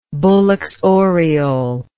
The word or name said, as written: Bullock's oriole